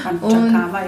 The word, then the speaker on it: Panchakarma: Habe ich es überhaupt richtig ausgesprochen? – Panchakarma und, ist ja, genau.